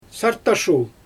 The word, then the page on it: sattashul: Xyzyl